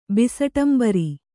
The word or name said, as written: ಬಿಸಟಂಬರಿ